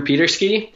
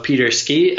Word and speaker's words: Peterski: My Instagram name is Drew Peterski. And I did that just because people always spell my last name wrong. So Peterski, S-K-I.